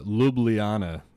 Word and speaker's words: Ljubljana: It's uh, Ljubljana is how you say it.